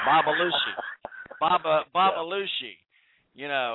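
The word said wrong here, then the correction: AlBalooshi: Abba. Not Baba. Abba. – Abolushi. – Abolushi, yes.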